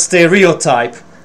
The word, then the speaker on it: stereotype: So the thing is, actually, it is pronounced stereotype, and the primary stress is on the first syllable, which is a big surprise to me.